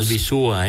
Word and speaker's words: Albizúa: Albizúa, Albizúa. – Que conste. – Recalcamos con acento en la U.